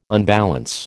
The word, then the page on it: unbalance: American English